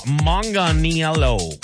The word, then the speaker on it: Manganiello: Meanwhile, Joe Manganiello, yeah, that's how you pronounce it,